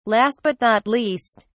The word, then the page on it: last but not least: British English